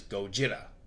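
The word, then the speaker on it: Gojira (ゴジラ): Actually, I believe they call him Titanus Kong as his specific scientific name, while Godzilla has Titanus Gojira. – Okay. – Oh, interesting fact. In Japanese, Kong is actually called Kongu, mm-hmm. – while Godzilla is Gojira.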